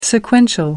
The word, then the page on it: sequential: American English